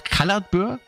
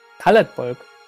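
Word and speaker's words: Caladbolg: Ja, viele sagen, sagst du auch Kaladbur? – Kaladbolg. – Bolg, okay, ja. Weil, so wird das auch im Spiel ausgesprochen.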